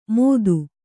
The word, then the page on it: ಮೊತ್ತು: kannada